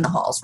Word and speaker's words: Nahal: Like there's not a ton of Nahals rolling around. There's a handful, but not a ton. And then with the accent, what it's actually supposed to be pronounced as in Farsi, it's Nahal.